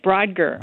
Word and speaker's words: Brodgar: It's called Ring of Brodgar. Did I pronounce that right? – You did. Brodgar.